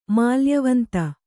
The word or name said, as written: ಮಾಲ್ಯವಂತ